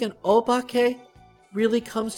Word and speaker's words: obake: O-B-A-K-E. Pronounced Obake.